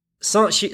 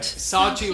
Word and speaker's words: Sanchi: And I'm going to pronounce it Sanchi Stupa. Sanchi Stupa. – Sanchi Stupa. – I put less on the N, you see, because it's a nasalized N. It's not spelt with a.